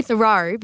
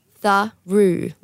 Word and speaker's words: Theroux: I always said Louis Theroux, but it's Louis Theroux, like you threw something. – Roux like kangaroo.